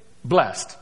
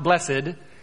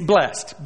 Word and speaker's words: blessed: And it is blessed. That's how you say it. It's not blessed. It's blessed. B-L-E-S-S-T. Even though it's spelled E-D. It's blessed.